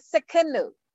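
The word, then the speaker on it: Tsidkenu: Jehovah Sikinu, that T is solid. You pronounce that Sikinu.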